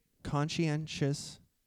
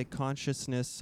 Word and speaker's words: conscientious: He was a conscientious objector. I think very good. – I said that right. – That was correct. – I never say it right. I usually say consciousness objector or it's a hard word to say, I think.